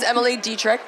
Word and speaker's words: Emily Detrick: So this is Emily Detrick.